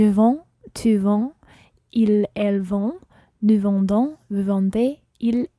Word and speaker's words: vendent: And il or elle plural vendent. – Ils, elles vendent. – And vendre is spelled V-E-N-D-E-N-T.